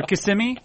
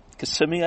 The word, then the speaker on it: Kissimmee: Or Kissimmee? How do you say it? Kissimmee? – Kissimmee, I believe.